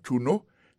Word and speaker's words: know: Knowledge, c'est K-N-O-W-L-E-D-G-E. Knowledge, ça veut dire connaissance. Il sortit du verbe « to know » qui veut dire « savoir ». Songez que c'est K-N-O-W. Alors ça, que je ne vais pas prononcer K, c'est parce que K, il est silencieux.